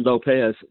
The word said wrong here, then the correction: Lopes: He's a Hispanic guy. – Yeah, it was Lopes, L-O-P-E-S.